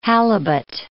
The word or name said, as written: halibut